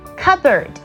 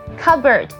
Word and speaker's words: cupboard: 再来就是我们家里非常常见的橱柜的英文 C-U-P-B-O-A-R-D, 你是怎么念的呢？看看你有没有念错哦，它是念 cupboard, cupboard, 有没有发现它不是 cupboard, OK, 因为它的 P 是不发音的，所以直接念 cupboard, 是橱柜哦。